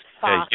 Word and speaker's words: Fast: What Jesper Fast, wasn't that it? – Fast. Yeah. It's Fast.